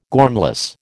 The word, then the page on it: gormless: American English